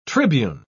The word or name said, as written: tribune